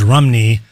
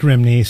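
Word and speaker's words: Romney: The correct pronunciation is Rumney, but it looks like Rimney, so I don't blame him.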